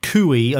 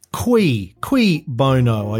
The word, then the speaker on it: cui bono: It's not cui. I think Tony's uh, harkening back to Australians going cui at the back. Qui, qui bono. I, I think it's produ- uh, pronounced in, in the Latin.